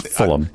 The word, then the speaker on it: Fulham: It's Fulham. – Now, to be totally fair to me, yeah. – It's spelled full space ham FC.